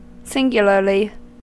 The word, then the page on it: singularly: American English